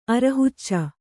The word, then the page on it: ಅರಹುಚ್ಚಿ: arahucci